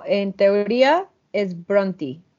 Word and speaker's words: Brontë: Porque es, The Bronte Sisters, las hermanas Bronte, o sea, aquí lo pronunciamos en español, pero en teoría es las hermanas Bronte, pero suena horrible en español, entonces las Bronte.